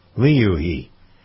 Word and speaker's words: Liu He: The delegation is led by Vice Premier Li He. Is that how you pronounce that? Li He? Louis? Louis He. Liu He.